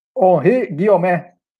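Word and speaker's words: Henri Guillaumet: Esse eu vou até vou pedir para o nosso amigo falar com a pronúncia francesa correta. Fale, pode mandar ver. Fale lá. Henri. – Henri Guiomé. – Aí, Henri Guiomé. Aí, tá aí.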